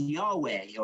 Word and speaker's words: Yahweh: This is the official name of God, often pronounced Yahweh or translated as Jehovah.